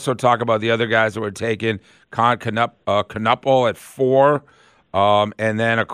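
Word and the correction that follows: Kon Knueppel: All right, I'll pronounce it for you. So you got both of them wrong, buddy. I love you. It's Con Knipple. Yes, it sounds like a part on the body. Con Knipple.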